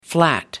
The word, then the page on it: flat: American English